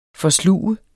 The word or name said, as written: forsluge